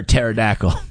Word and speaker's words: pterodactyl: Oh, I would call that a pterodactyl. – Okay. – But P. The P is silent.